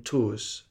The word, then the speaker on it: Tours: We're in Tours. Is that a correct pronunciation? What's going on? I'll let you pick this. – Yes.